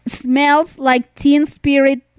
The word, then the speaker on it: Smells Like Teen Spirit: Mi Jordana, que es chilena, sí sabe pronunciar smells like teen spirit de Nirvana. Así, así se pronuncia.